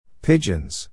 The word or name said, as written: pigeons